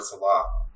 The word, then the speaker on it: Saleh: I got Robert Saleh. I think that's how you say his name, Saleh.